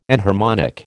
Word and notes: enharmonic: American English

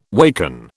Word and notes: waken: British English

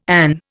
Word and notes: an: Indian

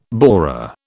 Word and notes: borer: British English

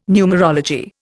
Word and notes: numerology: Indian

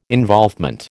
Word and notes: involvement: American English

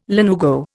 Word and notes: lanugo: Indian

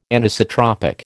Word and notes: Anisotropic: American English

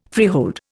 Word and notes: freehold: Indian

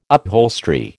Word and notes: upholstery: American English

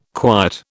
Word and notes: quiet: British English

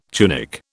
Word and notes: tunic: British English

